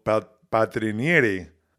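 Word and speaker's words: Paltrinieri: One's a high jumper, Gianmarco Tamberi, and then Gregorio Patrinieri, I believe is how we pronounce it, who's an Olympic swimmer for Italy.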